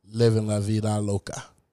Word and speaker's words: Living La Vida Loca: Well, Desmond Child is the one who wrote Ricky Martin's Living La Vida Loca.